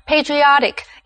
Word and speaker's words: patriotic: 所以这里的 O 就要发 R 的音，而且重音也变成第三个音节，念成 patriotic, 意思是爱国的。例如 patriotic songs 是指爱国歌曲。所以我们看的是appreciation 这个不可数的名词。